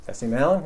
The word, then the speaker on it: Jesse Malin: His own version of Jesse Malin. Is that how you say his name? – Jesse Malin, yeah.